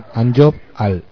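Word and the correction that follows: Q'anjob'al: Pues es Canjobal, si no estoy mal, creo que...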